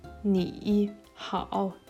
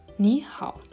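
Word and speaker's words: ni hao (你好): Aber hier noch eine Regel. Statt ni hao sagt man ni hao. Also, wenn die dritte Betonung sich mit einem anderen Drittbetonung trifft, ändert sich die erste Drittbetonung zu dem zweiten.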